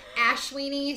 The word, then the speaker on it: Ashwini: And the first section of Aries is called, I might pronounce this wrong, but it's called Ashwini. – I think you pronounced it perfectly. Ashwini sounds exciting.